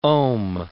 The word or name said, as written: om